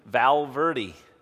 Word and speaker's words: Valverde: Valverde.